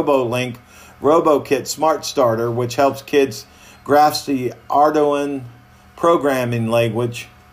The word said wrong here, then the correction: Arduino: I said that wrong. It's Arduino programming language as they command their toys to navigate mazes.